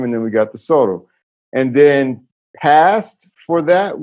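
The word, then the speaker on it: sọ̀rọ̀: So sorrow. – Sorrow. How do we spell that? – S-O-R-O. – S-O-R-O.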